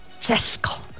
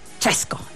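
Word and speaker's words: Česko: Y luego cuando se fundó el Automóvil Club de Chequia, que además se pronuncia Chesco, así, Chesco en checo, pues le entró a los motores, enloqueció como todo el mundo, y además el hombre se ponía a, pues, pilotaba sus coches.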